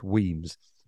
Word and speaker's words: Wemyss: Pronunciation of Weems, W-E-M-Y-S-S, pronounced Weems.